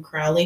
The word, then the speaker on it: Crowley: Around 11:30 p.m., Brandon called his father in Crowley, Texas.